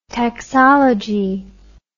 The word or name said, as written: taxology